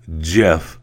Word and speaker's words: GIF: But remember that it is GIF, okay? Not GIF, okay? He is the inventor. He said in 2013, there's no hard G. It is pronounced GIF, like the peanut butter.